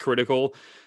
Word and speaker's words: KRTKL: It's spelled kind of weird, but it's pronounced critical.